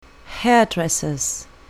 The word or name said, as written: hairdresser's